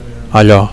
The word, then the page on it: allo: Catalan